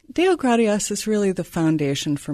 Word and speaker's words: Deogratias: Deogratias.